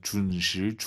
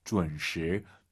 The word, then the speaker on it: 准时: 여러분, 지금 왕 선생님 발음 어때요? 준시, 준시 아니에요. UN 사이에 으어 들어가야 돼요. 준, 준시, 준시.